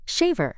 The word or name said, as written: shaver